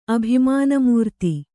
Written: ಅಭಿಮಾನಮೂರ್ತಿ

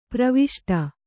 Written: ಪ್ರವೇಷ್ಟ

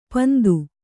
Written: ಪಂದು

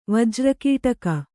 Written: ವಜ್ರಕೀಟಕ

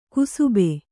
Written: ಕುಸುಬೆ

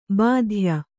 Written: ಭಿದ್ಯ

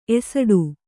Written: ಎಸಡು

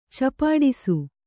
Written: ಚಪಾಡಿಸು

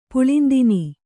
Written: ಪುಳಿಂದಿನಿ

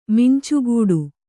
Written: ಮಿಂಚುಗೂಡು

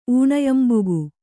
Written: ಊಣಯಂಬುಗು

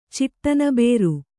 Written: ಚಿಟ್ಟನಬೇರು

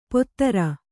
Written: ಪೊದರ್

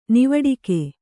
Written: ನಾವಾಡಿಗ

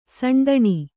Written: ಸಾಂಡಣಿ

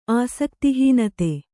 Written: ಆಸಕ್ತಿಹೀನತೆ

